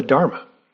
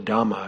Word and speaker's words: Dhamma: So the path that he discovered, that he came upon, we call it the Dharma, right? Or the Dhamma, if you prefer the Pali pronunciation.